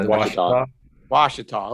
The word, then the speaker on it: Washita: They want to talk about great forests like the, I think you call it Washita. Is that how you say that? – Yeah. – Washita.